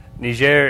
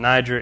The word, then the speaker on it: Niger: Niger is the French pronunciation, Niger, English. We mix them up all the time. Either one's correct.